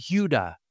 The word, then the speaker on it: Judah: Judah, or as pronounced in Hebrew, Yudah. Yudah sounds like the word for praise, Yada.